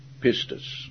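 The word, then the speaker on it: pistis (πίστις): The noun faith is pronounced in the Greek pistis, P-I-S-T-I-S.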